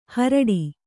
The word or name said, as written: ಹೋರಾಡು